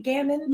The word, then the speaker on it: Gaman: Gammon? – You did, like backgammon. Uh-huh.